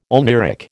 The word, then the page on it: oneiric: American English